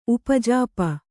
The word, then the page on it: ಉಪಜಾಪ: upajāpa